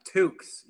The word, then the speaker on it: toque: Toques. Yeah. So it's spelled T-O-Q-U-E.